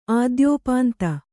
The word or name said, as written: ಆದ್ಯೋಪಾಂತ